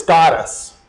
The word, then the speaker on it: skotos: If you look up the word darkness in the Greek, the word for it is skotos, if I'm saying it right, S-K-O-T-O-S.